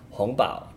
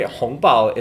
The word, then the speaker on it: hongbao: And by the way, hongbao is spelled H-O-N-G in the second tone. Hong, that literally means red. And then bao, B-A-O in the first tone, that literally means envelope.